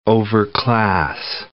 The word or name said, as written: overclass